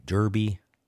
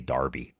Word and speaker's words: Derby: I prefer to say darby. Darby hook. It's like the Kentucky Derby. No, it's the Kentucky Derby. Never mind. Darby is how you pronounce it in England.